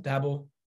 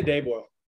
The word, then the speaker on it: Daboll: Is that how you pronounce it? Daboll? – I say Daboll.